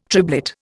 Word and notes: triblet: Indian

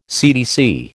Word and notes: CTC: American English

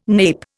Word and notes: nape: Indian

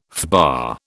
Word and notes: FBA: British English

